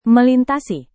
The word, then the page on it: melintasi: Indonesian